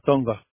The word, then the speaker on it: Tonga: Tonga. – T-O-N-G-A? – Yeah.